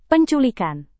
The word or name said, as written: penculikan